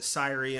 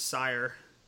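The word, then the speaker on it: Cyre: you called it Syrie, and I usually pronounce it Sire. – I've heard it called Seer. Or Seer works too.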